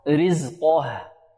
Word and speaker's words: رزقه: لا يفخم القاف ويرقق ماذا؟ الهاء. فقدر عليه رزقه رزقه.